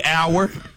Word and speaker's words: hour: Hour. – Hour. – Like time. – Yeah. – The H is silent. – Exactly. H-O-U-R, the H is silent.